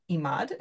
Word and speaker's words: Emad: Um, I think your last name is Emad. Is that how you say it? Yes.